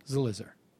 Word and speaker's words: Zelizer: I think I did a long I, and it's... a short eye. – Well, he didn't seem to mind. – Zelizer.